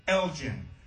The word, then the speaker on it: Elgin: It's a town in Illinois spelled E-L-G-I-N. It's Elgin. But there's a town in Texas right outside Austin, E-L-G-I-N. It's Elgin. They say there ain't no gin in Elgin.